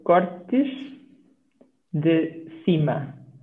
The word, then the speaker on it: Cortes de Cima: So it's Cortes de Cima. Okay. But we often say when we speak it, we speak quite quick, so we say Cortes So you kind of don't actually pronounce the D even though it's there.